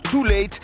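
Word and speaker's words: TUL8TE: Son nom s'écrit de manière stylisée avec un U et un 8, mais ça se prononce Too Late.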